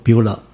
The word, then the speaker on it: Beulah: 你知道英文还有欧洲语系找不到一个字能够跟希伯来文这个 Bola 一样的意思的字，所以他们就多半就直接音译成拼成一个音很接近的字，所以就拼英文就拼成 BEULAH 读作 Bola。